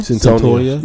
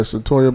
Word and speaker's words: Cyntoia: Is it Cynthia Brown? – Cyntoia. – Yeah, Cyntoia Brown.